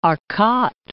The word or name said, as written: Arcot